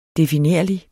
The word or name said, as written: definerlig